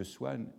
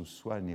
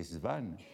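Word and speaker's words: Swann: il y a au moins deux occurrences où Swan est prononcé Svan par Norpois.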